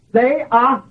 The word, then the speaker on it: theaomai: The second usage of the word beholding in the Bible is this word, theaomai. Theaomai. Theaomai. And that Greek word is spelled T-H-E-A-O-M-A-I.